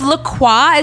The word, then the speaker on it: LaCroix: If La Croix is technically pronounced LaCroix, then I should have the freedom to say Glossier.